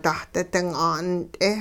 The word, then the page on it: dahdi-ding 'ant'e: hupa